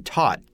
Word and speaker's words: taut: So it was, its pace is taut yet fun. Well, in America, you can say taut, T-A-U-T, and it kind of rhymes with not. In the UK, it's taut. So it didn't work.